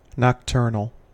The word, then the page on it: nocturnal: American English